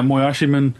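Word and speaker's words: Moyashimon: The one I'm actually getting ready to review volume two of, Moyashiman. – Mm-hmm. Is that correct? Moyashiman. – Yeah, Moyashiman, which is a really good manga.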